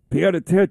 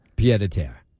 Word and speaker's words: pied-à-terre: Yesterday was a big day here in the city, too, talking about Mamdani for his pied-à-terre. How do you say it? – Pied-à-terre.